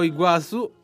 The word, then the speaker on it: Iguazú: Oltre 35.000 abitanti, è situata alla confluenza del rio Paraná con il rio Iguazú. Detto giusto? – Bravo, bravo.